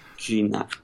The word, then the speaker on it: Gignac: They got Gignac. Gignac? I can't even say his name. – Gignac.